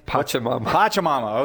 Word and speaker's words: Pachamama: Is it Patchy Mammy or what's the Packy Mammy, Pac-Man? – Pachamama.